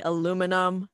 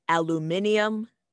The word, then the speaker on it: aluminum: I say aluminum. I have heard aluminium, but I don't know. What do you say, Vivon? – So the British say aluminium, which it sounds really fancy, and it's not even, like, such an important metal.